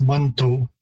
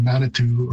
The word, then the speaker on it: Manitou: The word is typically pronounced Manto. So there's a number of different shifts again in terms of pronunciation from the spelling. The OU spelling is primarily obviously in the English and French pronunciation, Manitou or Manitou, is one possible variation of the sound.